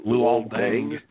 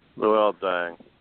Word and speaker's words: Luol Deng: Lu, Luol Deng. – Luol Deng.